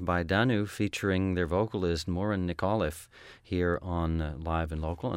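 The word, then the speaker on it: Muireann Nic Amhlaoibh: And the singer with the group, her name, he coached me on the pronunciation. It doesn't look anything like this unless you're Irish and you might figure it out. Morin Nicolliffe, and she is in this tune from their disc, which is called The Road Less Traveled.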